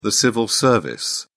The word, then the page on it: civil service: British English